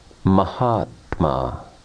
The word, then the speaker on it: Mahatma: A plus A equals A. Maha Atma. Maha Atma. Mahatma. Mahatma. The great Atma, self.